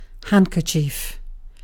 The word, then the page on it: handkerchief: British English